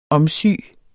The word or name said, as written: omsy